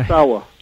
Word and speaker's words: Ozawa: Ozawa.